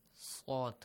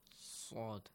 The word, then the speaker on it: sad: How do you say the letter Sad? – Sad. Sad. – You can say the word sad in English.